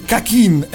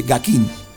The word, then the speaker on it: Gakin: Dico quasi perché nel 45 giri è proprio fedelmente Gakin, anche se con CK, mentre nella videosigla iniziale presentano una C e non una G, cioè quindi Kakin e non Gakin. Come si sia arrivati a pronunciare dolce la G, non saprei dirlo. In giapponese comunque è pronunciato come si scrive Gakin.